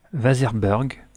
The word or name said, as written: vaserburg